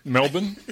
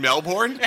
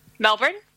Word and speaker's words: Melbourne: Like Melbourne? – You mean Melbourne? – How do you say that? – Melbourne. – Nice. Jeez, oh, you've been practicing.